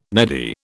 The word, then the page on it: neddy: British English